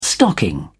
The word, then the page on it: stocking: British English